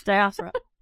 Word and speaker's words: diaspora: I can never pronounce that word in English. – Yes, diaspora.